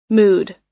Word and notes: mood: American English

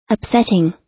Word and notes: upsetting: American English

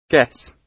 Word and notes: guess: British English

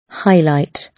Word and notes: highlight: British English

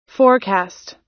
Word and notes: forecast: American English

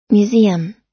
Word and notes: museum: American English

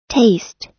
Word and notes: taste: American English

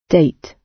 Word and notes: date: British English

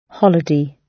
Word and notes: holiday: British English

